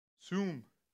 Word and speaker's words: sum: And that word is actually sum. Even though it looks like it says some, like give some. No, it's actually sum. S-O-O-M, sum. That's how you say it. In the Hebrew, the word give is sum. Say sum. Sum.